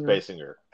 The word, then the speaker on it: Basinger: It's pronounced Basinger.